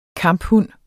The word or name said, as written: kamphund